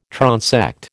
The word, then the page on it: transect: American English